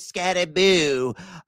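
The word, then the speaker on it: Skattebo: It is not Scattaboo, folks. We got to pronounce it correctly. It is Scattaboo.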